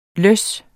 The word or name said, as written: løss